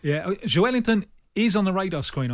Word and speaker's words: Joelinton: If you start with Joe, you won't. So yeah. Joelinton.